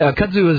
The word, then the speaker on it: kudzu: Bigger than ever, and growing like kudzu, thanks to the free spending... I hope I pronounced that right. – It's kudzu.